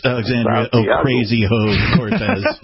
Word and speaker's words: Alexandria Ocasio-Cortez: I'm sorry, it's Alexandria Ocasio-Cortez. I just want to correct everybody's pronunciation.